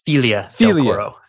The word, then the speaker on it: Figli del Coro: It's technically pronounced Filia del Coro. – Filia de Coro. This was it's spelled Figli del Coro, but it's pronounced Filia. It's Italian.